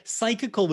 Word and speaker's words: Psychical: Psychical was just this 19th century term for psychic. They put the AL on the end to make it sound more science-y.